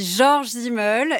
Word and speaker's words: Georg Simmel: » Oui, Georg Simmel quelqu'un... – Ah, déjà, mettons, voilà, moi je dis Georges Simmel, et je savais que j'allais être retoquée immédiatement Moi je vais le prononcer en français, parce que vraiment...